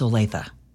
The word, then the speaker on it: Olathe: Just a woman that replaced me that didn't know how to pronounce Olathe. – Well, it is pronounced Olath.